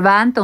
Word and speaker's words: Venta: Eh, Venta, protams, ir Venta un Ventspils pēc visiem, kā jūs sakāt, gramatikas likumiem, tie būs pareizrunas likumi, un jūs kā Ventspilniec ļoti netipiski teicāt pareizi.